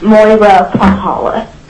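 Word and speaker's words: Moira Pyhala: We'll go to, is it Maura? Bela? – I'm sorry, it's Moira Pahala. – Okay. I'm sorry I mispronounced so badly. It's M-O-I-R-A and last name P-Y-H-A-L-A.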